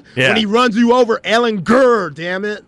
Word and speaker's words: Ehlinger: pronounce Sam's name with the soft G, not the hard G. It really got annoying. – Brutal. – Yeah, we need to make sure that everybody knows. Yes. Ehlinger. – Ehlinger. – Ehlinger. Get your grr.